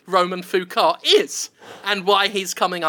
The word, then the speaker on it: LeBeau: Roman LeBeau pronounces his surname Foucault. – Roman Foucault? – Or car, I guess. Foo-K-O-H. Foucault. But it's spelled L-E-B-E-A-U.